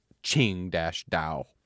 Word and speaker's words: Qingdao: Just say, and then it's pronounced Qing-Dao.